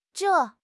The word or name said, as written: zhei4